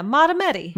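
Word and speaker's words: Mahtomedi: Tell me how to spell it. – M-A-H. – M-A-H. – T-O. – T-O. – M-E-D-I. Montemedi, Minnesota. – Yeah, Matameti.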